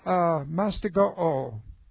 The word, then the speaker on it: mastigo: This is the present active indicative of mastigo. And it looks like this. Okay, this is M-A-S-T-I-G-O, long O.